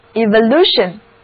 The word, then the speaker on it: evolution: 因为字母 E 表示向前， T-I-O-N 是名词后缀，而字母 O 要读作 A Evolution Evolution，重音就在字母 I 所在的音节。